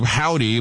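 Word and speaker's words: Hovvdy: can pronounce the name of that group, Howdy, but it's spelled with two V's, H-O-V-V-D-Y.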